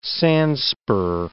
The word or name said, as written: sandspur